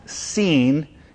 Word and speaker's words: Sin: Now, the wilderness is not called the wilderness of Sin because the Israelites sinned there. It just so happens that the transliteration of the Hebrew word, which is more accurately pronounced seen, is transliterated into English as sin.